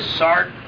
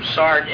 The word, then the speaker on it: Sartre: If you take out that page that says Sartre on the top,